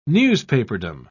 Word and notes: newspaperdom: British English

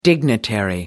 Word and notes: dignitary: American English

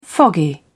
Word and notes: foggy: British English

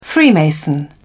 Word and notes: freemason: British English

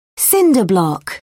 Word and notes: cinder block: British English

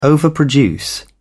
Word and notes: overproduce: British English

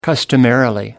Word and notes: customarily: British English

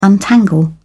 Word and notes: untangle: British English

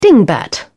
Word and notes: dingbat: British English

